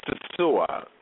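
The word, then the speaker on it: Sosúa: How do you pronounce the place where you live in the Dominican Republic? – Okay, it's right now it's after 3, and correct pronunciation is Sisua.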